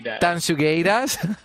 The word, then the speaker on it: Tanxugueiras: Bueno, pues con Tansungueiras, ¿bien? ¿Lo he dicho bien? – Tansugueiras. Nos despedimos hasta el siguiente programa, Oscar.